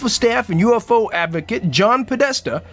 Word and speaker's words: John Podesta: New Obama advisor John Podesta.